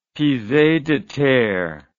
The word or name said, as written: pise de terre